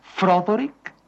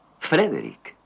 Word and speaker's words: Frederick: ¿Dice usted también Froderick? – No, Frederick. – ¿Y por qué no es Froderick Frankenstein? – Porque no, es Frederick Frankenstein.